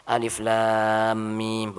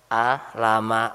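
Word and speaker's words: Alif Lam Mim (الم): Bukan hurufnya disambung dikasih harokat, tidak, tetapi dipotong-potong. Alif Lam Mim, bukan A, Lama, atau Kahaya, Aso, gitu. Mau difasih-fasihkan kayak apa, tetap salah, gitu.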